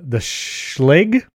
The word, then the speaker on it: Schlage: It's basically the Schlage.